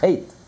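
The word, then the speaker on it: chet (חטא): As the Baal Shem Tov said, the Hebrew word for sin, chet, is spelled ches, tes, aleph. But the aleph is silent. It could have been written just ches, tes. The aleph doesn't add anything. It's completely silent. Chet.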